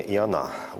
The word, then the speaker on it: Иона: Да, здесь все-таки с таким с еврейским вариантом произношения «Иона», ударение на «а».